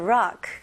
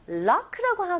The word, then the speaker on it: rock: 그래서 lock라고 하면 잠그다라고 되거든요. 그래서 발음을 정확하게 하셔야 돼요.